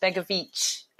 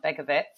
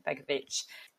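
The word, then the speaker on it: Begović: So Asmir Begovic is pronounced Begovic at the end and not Begovic. Although in my defence, there's no line above the C when I've seen it written in the press. So I was pronouncing it the way I thought it should be pronounced, but it's actually Begovic.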